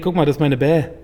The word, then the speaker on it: BAE: B-A-E. – Ich glaube, das wird bäh ausgesprochen. Also wie, also wie Baby.